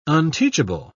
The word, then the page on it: unteachable: British English